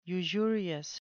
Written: usurious

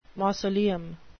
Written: mausoleum

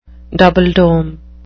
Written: double-dome

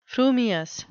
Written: frumious

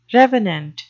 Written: revenant

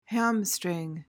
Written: hamstring

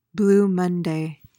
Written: blue monday

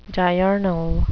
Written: diurnal

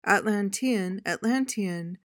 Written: atlantean

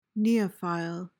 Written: neophile

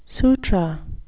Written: sutra

